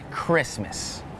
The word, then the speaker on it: Christmas: Some examples with a silent T are the words listen, the word castle, and the word Christmas.